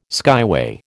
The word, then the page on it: skyway: American English